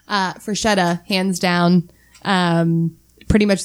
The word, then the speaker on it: Freshetta: So I chose, I'm going to butcher this name, so someone might need to help Freshetta.